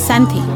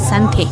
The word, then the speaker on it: Sante: pero estamos en territorio Ñañú y es Sante, y así se debe de pronunciar Sante, que es la fibra del maguey.